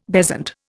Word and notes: bezant: Indian

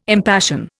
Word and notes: impassion: Indian

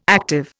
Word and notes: active: Indian

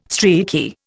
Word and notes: streaky: Indian

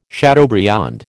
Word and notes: chateaubriand: American English

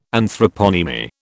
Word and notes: Anthroponymy: British English

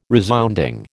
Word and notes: resounding: American English

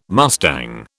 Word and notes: mustang: British English

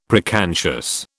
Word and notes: preconscious: British English

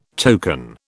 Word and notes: token: British English

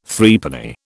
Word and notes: Threepenny: British English

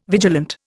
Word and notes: vigilant: Indian

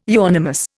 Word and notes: euonymus: Indian

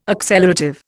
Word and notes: accelerative: Indian

